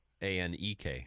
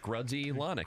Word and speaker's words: Grudzielanek: Grudziel. Enak. – Close. – Thanks. Anak. – Anek. – Anek. Okay. – Yeah. It took me like five times to spell that right today. – Just Grudzielanek.